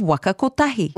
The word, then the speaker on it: Waka Kotahi: Hilary Barry does well too here in her pronunciation of Waka Kotahi.